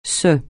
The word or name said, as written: ce